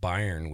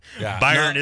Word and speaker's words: Bayern: Bayern is the correct pronunciation.